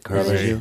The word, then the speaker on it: Caravaggio: Caravaggio? – Mm-hmm.